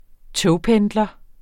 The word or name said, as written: togpendler